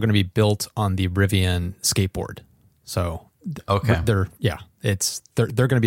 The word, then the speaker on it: Rivian: But well, I think that the Rivian is one. Did I say that properly? – Yeah, Rivian.